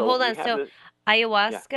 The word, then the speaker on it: ayahuasca: it definitely is pronounced like a Spanish word. So, ayahuasca is A Y A H U A S C A ayahuasca.